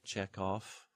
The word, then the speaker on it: Chekhov: In Russian words and names, final V becomes an F, so we get Chekhov or Romanov. So final V, Peter Ustinov, written with a V, pronounced with an F sound.